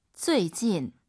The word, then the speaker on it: 最近: 最近, UI 사이에 에 넣으셔야 돼요. 우리 선생님 발음 들어보세요. 쭈이찐, 쭈이찐이 아니에요.